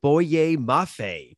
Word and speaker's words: Boye Mafe: We're going to go with Boye Mafe.